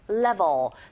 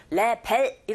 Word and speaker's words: level: 여기 빈칸에 I'm at over 3,800 meters sea level. 레벨 이렇게 들리지 않고 level 이렇게 발음을 하시고 그렇게 들으셔야 돼요. 자, 내가 해발 3,800m 이상에 있다는 것을 깨달았어요.